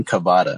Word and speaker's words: Cavada: My name is Adrian Cavada.